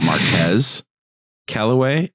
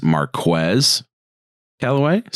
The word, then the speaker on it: Marquez Callaway: Uh, I need to evidently pronounce his name correctly. It's not Marquez Callaway. It is Marquez Callaway.